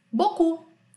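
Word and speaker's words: beaucoup: Então, regra número 2, não se pronuncia a última consoante da palavra, como por exemplo, boku que a gente falou agora há pouco, que significa muito. Eu não falo bo, bocupe, né? Bocu, ó, toujours, que significa sempre.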